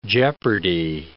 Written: jeopardy

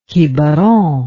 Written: Quiberon

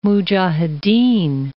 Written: mujahideen

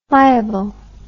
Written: flyability

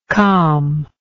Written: calmingly

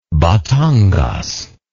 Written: Batangas

